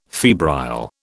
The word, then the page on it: febrile: British English